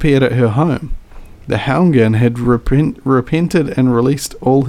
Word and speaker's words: Houngan: Houngan.